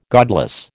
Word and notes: godless: American English

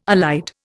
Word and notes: allied: Indian

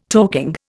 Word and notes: talking: Indian

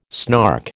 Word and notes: snark: American English

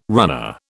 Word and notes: runner: British English

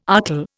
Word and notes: archil: Indian

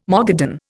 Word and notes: Mogadon: Indian

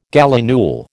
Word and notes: gallinule: American English

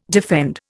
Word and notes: defend: Indian

